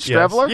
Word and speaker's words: Streveler: Streveler? – Go ahead. – Was it Streveler? Was it Streveler? – Yes. Yeah, no, it is.